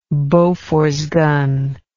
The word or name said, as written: Bofors gun